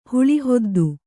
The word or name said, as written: ಹಾಳಿಹದ್ದು